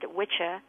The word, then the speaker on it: wicca: A man was called a witcha, spelled W-I-C-C-A, pronounced witcha in Old English. And a woman was a witchy, W-I-C-C-E.